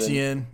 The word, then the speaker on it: Etienne: the yards per carry nationally leaders are Travis Etienne. I think that's how you say his name at Clemson. – Etienne, yep.